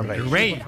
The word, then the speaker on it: Raye: Ray. Ray. Se escribe Raye. – Raye con ella.